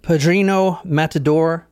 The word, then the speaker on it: Pedrinho Matador: All right, tonight we're talking about Pedrinho Matador.